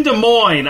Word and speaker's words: Des Moines: You're in Des Moines, Iowa. – Drent, you sound like a real gringo right now. It's pronounced Desmoines.